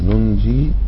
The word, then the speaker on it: ننجي: فكان احيانا في الرسم يحذفون الياء لأنها لا تنطق أصلا فهو الأساس يخففها وبالتالي يلتزم في القراءة عند الوقف عليها حذف الياء أحسن فنقول كذلك حقا علينا ننج إذا وقفت عليها كذلك حقا ننج وننجي المؤمنين